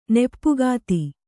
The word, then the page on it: ನೆಪ್ಪುಗಾತಿ: kannada